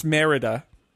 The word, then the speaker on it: Merida: It's pronounced Merida, as far as we can tell, the protagonist in Brave.